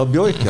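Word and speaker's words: Buick: Eller som en leser spørte om i gamle Amcar, uttales det bjuk eller bjøyk? – Ja, bjøyk, ja. Det sto det faktisk i den store svenske bilboken min. Bjøyk. På sånn lydtegn.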